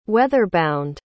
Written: weather-bound

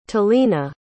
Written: talena

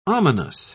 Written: ominous

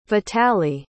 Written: vitaliy

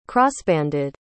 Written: crossbanded

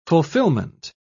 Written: fulfillment

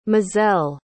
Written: mozelle

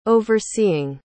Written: overseeing

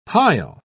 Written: pile

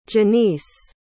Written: jenise

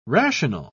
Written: rational

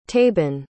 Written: taban

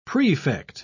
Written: prefect

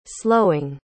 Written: slowing

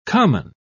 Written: common

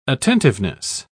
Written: attentiveness

